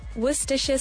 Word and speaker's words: Worcestershire: And the correct pronunciation actually is Worcestershire sauce.